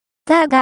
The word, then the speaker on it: TÁR: 読み方、ターが、